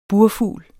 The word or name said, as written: burfugl